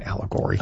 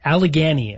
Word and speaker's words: Allegheny: How did you say that? What was that place called? – Allegory. – It wasn't Allegory. – Allegheny is how I called it. – Can you spell it for everybody? – A-L-L-E-G. Oh, hold on. A-N-Y.